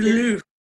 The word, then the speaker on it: Louvre: The the biggest one that got my attention is how do you pronounce it? Is it the Louvre? Louvre. The Louvre. Louvre? Yeah, I knew your ass would know. You know, I went there a hundred years ago.